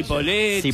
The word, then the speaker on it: Cipolletti: O Cipolletti, no sé cómo se pronuncia. – Cipolletti. Cipolletti. Cipolletti.